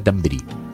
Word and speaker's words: Thunberg: No, perdona, lo has escrito mal entonces, Davidiete, porque nos lo explicó la propia Greta Thunberg, que se dice Greta Thunberg. Thunberg. Greta Thunberg.